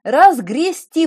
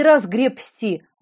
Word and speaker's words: разгрести: «Разгрести» произносится, а не «разгребсти». От слова «грести».